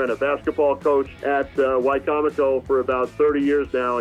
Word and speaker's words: Wicomico: I hope I'm pronouncing this school right. Wicomico? – Close. Wicomico.